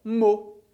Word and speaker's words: mot: O termo mot, quando termina em S, está no plural, mas se ele tivesse no singular, ia terminar por T. Ainda assim, você tem que continuar dizendo só mot. Isso quer dizer que quando você vira uma palavra que termina em T, esse T não vai ser pronunciado. Por isso a gente tem mot no singular e mot no plural, a pronúncia é exatamente a mesma.